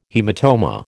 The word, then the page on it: haematoma: American English